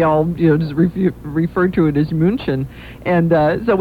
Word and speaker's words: München: They got around and they said, you mean München. – München.